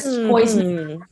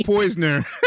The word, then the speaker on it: Poyser: Now everybody says Poyser. – Who's that? – Gosh, don't do that. – Can we discuss the actual pronunciation of his name? – No, say Poisner.